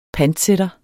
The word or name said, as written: panter